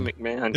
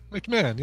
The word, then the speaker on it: McMahon: How do you say that? – I just say McMahon. – Is it just McMahon? – McMahon, yeah. – McMahon. Why does that look like wrong? – Ed McMahon had that extra syllable that you didn't pronounce.